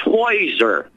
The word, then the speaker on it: Heuser: His name was pronounced Heuser. So right. I would presume that's spelled H-E-U-S-E-R.